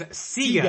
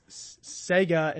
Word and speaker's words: Sega: Because Sega and Mega are pronounced the same thing, right, except different letters.